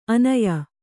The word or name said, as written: ಆನಯ